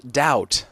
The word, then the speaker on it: doubt: Random letters appear that do weird things, and we see that today in the word doubt. There's a silent B. A silent B? Doubt.